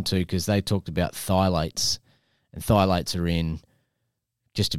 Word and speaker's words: phthalates: P-H-T-H-A-L-A-T-E-S. – Phthalates.